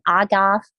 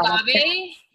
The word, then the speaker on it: agave: I can never pronounce his name, agave syrup. – Oh, no, agave. Yeah.